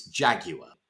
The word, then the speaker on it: Jaguar: However, the way they pronounce the name of that very famous British car, Jaguar, that drives me up the wall. It's Jaguar.